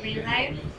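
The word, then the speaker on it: Green Lyf: My name is Melissa, and I go by Melissa Green Life.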